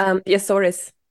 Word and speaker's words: Açores: And I remember that you went to Açores, les Açores. I don't know how to say this. – Azores. – Yeah, Açores. – Azores, Azores, yep.